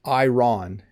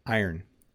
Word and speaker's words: iron: This is a hard word for English learners to pronounce. It's not iron, it's iron. Iron. There are a couple of different pronunciations, I think, but in my part of the world, we say iron.